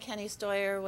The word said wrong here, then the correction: Steny Hoyer: was there too, or did I say his name right? That didn't sound right. Anyway. – Steny Hoyer. – Steny Hoyer, thank you.